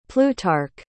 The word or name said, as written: plutarch